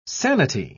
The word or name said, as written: sanity